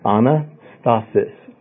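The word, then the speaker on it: anastasis (ἀνάστασις): It's anastasis.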